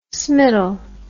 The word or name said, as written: smittle